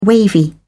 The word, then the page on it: wavy: British English